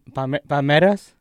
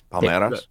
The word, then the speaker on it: Palmeiras: Inter Miami, they play Palmeiras. That's how you pronounce it. – Yes, Palmeiras.